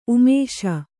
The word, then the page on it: ಉಮೇಶ: Kannada